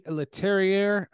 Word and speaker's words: Leterrier: The friction between Louis Leterrier, I think I'm saying his name correctly.